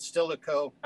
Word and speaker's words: Stilicho: Am I pronouncing his name right? Flavius Stilicho? – I pronounce it Stilicho, Alaric and Stilicho.